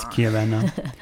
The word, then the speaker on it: Chiavenna: But it's Chiavenna.